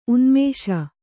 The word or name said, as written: ಉನ್ಮೇಷ